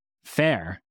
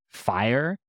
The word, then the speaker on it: Fayr: There were so many stupid ones, but then there was one F-A-Y-R and we were like, oh, fair. That's cool. But the problem is that people pronounce it like fire.